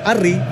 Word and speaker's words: Harry: Harry, porque la H no se pronuncia.